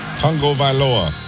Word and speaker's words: Tagovailoa: Tua... – Tagovailoa.